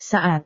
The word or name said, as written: saat